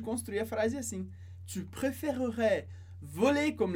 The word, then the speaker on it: préférerais: Se a gente fala bem devagar, a gente pronuncia esse E no meio, sem acento, e ficaria préférerais.